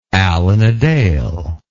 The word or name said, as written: Alan a Dale